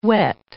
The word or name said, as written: wet